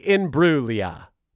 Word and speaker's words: Imbruglia: Natalie Imbruglia. That's how you pronounce it. You don't pronounce the G. The G is silent.